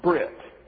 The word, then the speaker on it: berit: B-E-R-I-T, pronounced Brit.